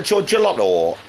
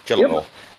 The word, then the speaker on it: Gelotto: So Gelotto. That's how you pronounce it, yeah? Gelotto. Yep.